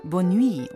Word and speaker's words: Bonne nuit: Bonenuit kenne ich nicht. – Das spricht man Bonne nuit und das ist französisch und heißt auch wieder gute Nacht.